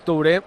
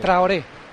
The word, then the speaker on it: Traoré: Es Touré muy mejorado en el aporte al equipo. – Sí. Traoré.